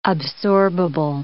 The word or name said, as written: absorbable